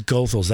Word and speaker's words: Goethals: What's the ethnicity of that, Goethals? – I think it's German. – Okay, so I should be switching my pronunciation of that. – It is Goethals.